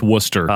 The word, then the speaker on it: Worcester: It's pronounced Worcester. – Oh, sounds like wussy.